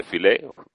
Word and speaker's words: Philae: Efectivamente, Philae es un monolito, pero es un monolito que estaba en la isla de Philae, en castellano se dice Philae, con acento en la E,